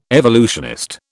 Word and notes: evolutionist: British English

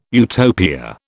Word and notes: Utopia: British English

